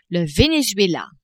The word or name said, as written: Venezuela